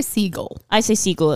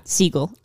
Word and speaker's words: Segel: I say Segel as well. – Like the bird. – Segel. I like to think of it. He's the Muppets guy. struggling musician Peter Bredder, played by Jason Segel, is better known as the boyfriend of TV star Sarah Marshall, played by the one and only Kristen Bell.